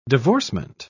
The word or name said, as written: divorcement